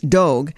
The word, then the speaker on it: Dogue: Dogu, which rhymes with, well, is it Dogue? Okay, it rhymes with Vogue, so it's Dogue, opened last month in the city's trendy Mission District.